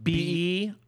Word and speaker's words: beIN: Is it BEIN? – Oh, because they wanted to be in the sport.